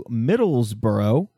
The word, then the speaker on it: Middlesbrough: So I'm going to head us back to the UK, where we're going to go to Middlesbrough.